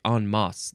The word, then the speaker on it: en masse: I feel like en masse, that's the proper way to say it. E-N is the first word. The second word is M-A-S-S-E.